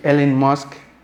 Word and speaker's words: Elon Musk: La semana pasada Nuevamente dijeron que Elon Musk, creo que lo pronuncié bien, ¿verdad? Ah, yes. Eh, Elon Musk es un hombre, uno de los hombres más ricos de nuestro planeta Tierra.